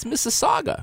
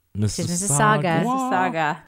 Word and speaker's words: Mississauga: When I looked at the map, I said, that's Mississauga. – That's right. – There's no U at the end. It's just an A. – That's right. No Mississauga. – Mississauga.